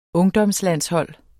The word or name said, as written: ungdomslandshold